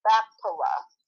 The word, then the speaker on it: Machpelah: It was the cave place, and I might be mispronouncing it, but it's M-A-C-H-T as in Paul, E-L-A-H, Machpelah,